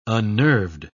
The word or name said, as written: unnerved